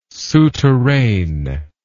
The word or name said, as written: souterrain